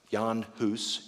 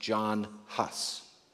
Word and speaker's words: Jan Hus: Let me tell you the story of one of my heroes, the Bohemian priest and reformer, Jan Hus. You might have heard his name pronounced John Huss.